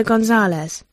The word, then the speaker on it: Gonzalez: My name is Maria Teresa Gonzalez. – Maria Teresa Gonzalez. How do you spell that? – G-O-N-Z-A-L-E-Z.